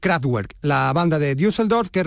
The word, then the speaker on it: Kraftwerk: donde sobre la melodía podemos escuchar la correcta pronunciación de la palabra Kraftwerk. 3, 2, 1, central eléctrica, Mr. Fly. – Kraftwerk.